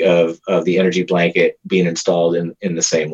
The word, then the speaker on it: ENRG Blanket: And so this, and it's spelled ENRG blanket. And so Energy Blanket, you know, stands on its own.